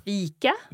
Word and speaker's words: fika: So you have a long E sound in fika. So E. And in ficka, I.